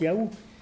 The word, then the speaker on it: YHWH: Et donc, ils savent que YHWH, ça se prononce Yahou, puisqu'on prononce encore le nom Nathaniahou. Donc, ils savent que ça se prononce Yahou, YHWH.